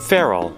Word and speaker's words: ferrule: The piece of metal on the end of a pencil that holds the eraser is called, is that ferrule? Is that how you pronounce it? – Ferrule. – Ferrule. It's called the ferrule.